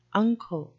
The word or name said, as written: unco